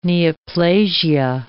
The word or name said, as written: neoplasia